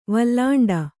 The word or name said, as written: ವಲ್ಲಾಂಡ